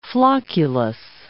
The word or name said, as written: flocculus